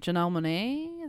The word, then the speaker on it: Janelle Monáe: Janelle Monáe? Is that how you say it? – Yeah.